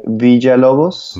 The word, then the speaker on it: Villalobos: So it would be something like Villalobos.